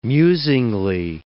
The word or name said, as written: musingly